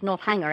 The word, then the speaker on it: Northanger: It depends on who's saying it. In our sort of dialect, in our part of the world, it often comes out as Northanger, actually. So different people pronounce it different ways.